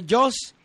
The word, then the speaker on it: JAWS: JAWS, se pronuncia JAWS.